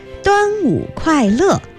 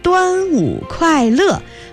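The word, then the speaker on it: Duanwu Kuai Le (端午快乐): Except that your pronunciation needs to be improved. It should be Duanwu Kuai Le, Happy Dragon Boat Festival. Duanwu Kuai Le, or Duanwu An Kang.Wishing you health during the Duanwu Festival.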